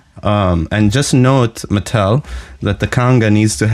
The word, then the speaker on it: Mattel: Like, you should say it's an American brand, right? So you should say it like an American, correct? – Yes. – It's Mattel. – Mattel.